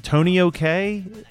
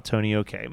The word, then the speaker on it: Tonio K: Do you know how this is pronounced? Tony OK? Is that? – I think that's right.